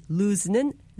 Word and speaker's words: lose: 발음할 때 L-O-O-S-E, lose는 S로 끝납니다. 반면에 L-O-S-E, lose는 Z로 마무리합니다. Lose.